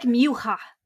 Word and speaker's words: Mucha: But Alphonse Mucha, and by the way, I didn't know that you are supposed to pronounce his last name as like either Mucha or Mucha. – Mucha. Didn't know that the other day. Yeah, that's, I think, the Czechoslovakian way of pronouncing it.